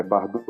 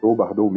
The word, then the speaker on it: Bardow: Bom, eu sou o Bardou, é, Bardot, Bardot mesmo, B-A-R-D-O-W.